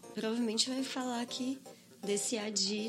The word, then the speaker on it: Addyi: existe uma droga chamada ADI é meio a pronúncia é assim mas escreve A D D Y I que é uma pílulazinha rosa por incrível que pareça que é ah, um.